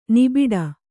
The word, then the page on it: ನಿಬಿಡ: nibiḍa